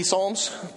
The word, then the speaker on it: Psalms: I decided I'm going to boycott silent letters. You know what it says? It's a waste of a perfectly good P, right? P Psalms? Psalms?